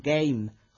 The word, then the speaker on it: game: Y palabras que se escriben con A, pero se pronuncian con sonidos totalmente diferentes, como por ejemplo game, juego, que se pronuncia con el diptongo A.